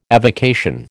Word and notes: avocation: American English

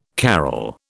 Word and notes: carrel: British English

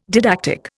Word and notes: didactic: Indian

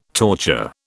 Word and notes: torture: British English

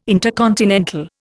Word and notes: intercontinental: Indian

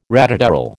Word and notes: ratatouille: American English

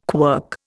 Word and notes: quirk: Indian

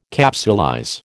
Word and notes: capsulize: American English

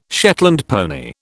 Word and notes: Shetland pony: British English

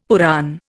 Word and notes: Purana: Indian

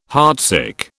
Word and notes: heartsick: British English

